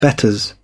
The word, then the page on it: betters: British English